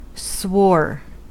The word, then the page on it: swore: American English